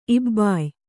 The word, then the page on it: ಇಬ್ಬಾಯ: ibbāya